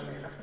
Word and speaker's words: Melach: La parola sale si scrive Melach, si pronuncia Melach, e sono le stesse tre consonanti, M, L, H, cambia l'ordine.